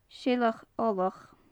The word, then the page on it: seileach ollach: gaelic